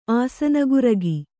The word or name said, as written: ಆಸನಗುರಗಿ